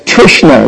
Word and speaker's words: Trishna: There's Krishna with a K, and there's Trishna with a T. Yeah? Trishna. It means hankering.